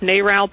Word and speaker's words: NARAL: Is it pronounced Narrow or Gnarl or N-A-R-A? – NARAL Pro-Choice America.